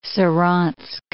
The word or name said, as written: Saransk